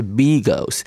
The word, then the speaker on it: beagles: 有很多不同种类的狗，比方说贵宾狗、英国斗牛犬，还有米格鲁猎犬啊。这个米格鲁猎犬呢，其实从发音来它是 beagles，不晓得为什么会翻译成米格鲁猎犬呢？啊，实际上是一个 b 的发音才是对的。